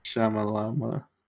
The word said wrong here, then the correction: Shyamalan: S-H-Y-A-M-A-L-A-N. – Yeah, there's no way. – So why? – No. It's Shy Am Alan.